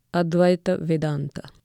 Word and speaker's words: Advaita Vedanta: They are Sankhya, Yoga, and Advaita Vedanta.